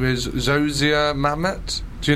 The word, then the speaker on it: Zosia Mamet: So the correct way to pronounce Zosia Mamet is... – Zosia Mamet.